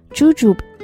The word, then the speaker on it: jujube: jujube 它就是枣的意思，这个单词它的发音和拼写都非常的有趣，它最后还有一个 b 的音，但是因为 b 和 p 连在一起，所以 b 它发的音不是特别明显，但是拼写的时候千万不要把它漏掉了。